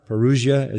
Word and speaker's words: parousia: The word here is parousia, or parousia, as you sometimes hear it pronounced, parousia.